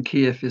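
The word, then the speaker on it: Kyiv: Well, yeah, Kiev is the Russian and Kiev is the Ukrainian pronunciation.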